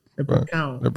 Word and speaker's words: leprechaun: Leprechaun. – Leprechaun.